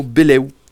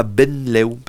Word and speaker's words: benleu: Alors, on prononce beleu et pas benleu. Parce que ça s'écrit B-E-N-L-E-U. Et on va pas prononcer benleu, on va prononcer beleu.